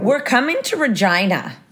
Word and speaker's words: Regina: And if you Americans think that it's Regina, it is Regina. Yes. – I'm going to call it Regina, though. I'm changing it. – It rhymes with vagina.